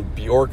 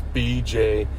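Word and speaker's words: Björk: It's BJ, it sounds BJORK, but you pronounce Bjork.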